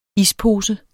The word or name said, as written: Ispose